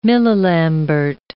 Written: millilambert

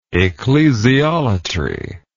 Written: ecclesiolater

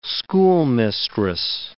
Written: schoolmistress

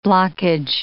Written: blockage

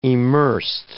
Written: emersed

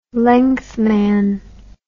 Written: lengthman

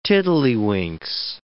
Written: tiddlywinks

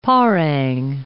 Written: parang

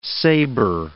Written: sabre

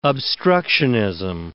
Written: obstructionism

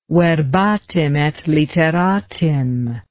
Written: verbatim et literatim

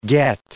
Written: get

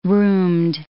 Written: roomed

